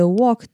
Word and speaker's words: walk: 那它这里的 walk 的 A 一样是短母音，然后它的 L 是不发音的。